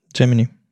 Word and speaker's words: Gemini: Gemini.